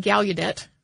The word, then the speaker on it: Gallaudet: And this is essentially a student protest that changed the course of both Gallaudet University. I have also heard it pronounced Gallaudet with like the U sound. I've heard people pronounce it both ways.